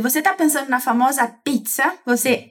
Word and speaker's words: pizza: Pois bem, essa palavra tem dois Zs e é falada como se no lugar desse Z a gente tivesse um TS. Tss. Esse tss, que seria um TS, é uma das pronúncias que a letra Z tem em italiano. Se você fala pizza em português, você também fala pizza em italiano. A pronúncia é igual.